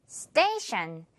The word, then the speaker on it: station: T-I-O-N 부분, 션 처리해주면서 Station, 역이라는 뜻이죠.